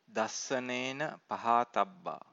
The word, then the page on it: dassanena pahatabba: Pali